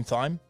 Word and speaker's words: thyme: "Do you mean thyme?" And they're like, "That's thyme." I was like, "No, I've got a clock. That's the motherfucking time." Okay. How is T-H-Y-M-E pronounced time? – Okay, you're gonna hate this one then. Spell phlegm. – F-L-E-M.